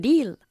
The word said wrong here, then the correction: dial: ¿Y cómo se pronuncia? – To dial. – Eso es, to dial, vale.